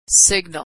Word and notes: signal: American English

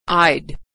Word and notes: I'd: American English

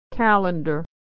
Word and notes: calendar: American English

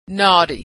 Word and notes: naughty: American English

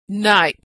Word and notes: night: American English

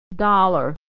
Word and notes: dollar: American English

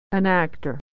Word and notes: actor: American English

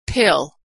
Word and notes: pill: American English

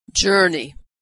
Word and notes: journey: American English

